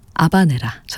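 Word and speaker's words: habanera: 비제 오페라 까르멘 가운데 하바네라, 아바네라 전해드렸습니다. 고티에 카피송의 첼로, 파리 취임버 오케스트라의 연주였습니다. 원어 발음에는 또 아바네라라고도 또 저희가 소개해드리곤 하는데요.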